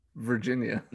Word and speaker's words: Virginia: How do you say Virginia? – Virginia. – No. Virginia.